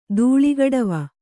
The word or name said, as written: ಧೂಳಿಗಡವ